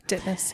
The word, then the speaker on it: Ditmas: Ditmas, yeah.